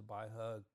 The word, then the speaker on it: Pai: Now that might sound a little bit different, but actually it's Wei Giles, and it's pronounced very similarly to the Baihe Gujin Tang. So even though it's spelled P-A-I, it's still Bai.